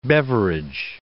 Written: Beveridge

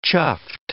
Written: chuffed

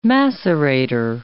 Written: macerator

